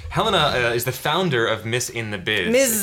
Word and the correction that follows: Ms. in the Biz: Ms. in the Biz. – Yes, Ms. because it rhymes.